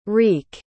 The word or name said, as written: rique